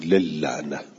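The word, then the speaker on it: للعنة: لعنت هم همینجوران، یه لام لام فاعل فعل، یه لام لام الف لام، یه لام لام حرف جر، تو تلفظ ادغام میکنیم، ما میگیم للعنه، ولی تو نوشتن سه تا لام باید بنویسیم، دوتاش غلطه.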